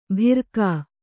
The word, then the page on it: ಭಿರ್ಕಾ: bhirkā